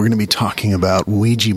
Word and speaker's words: Ouija: It's pronounced Ouija, not Ouija. It comes from the French and German words for yes. We. Ouija.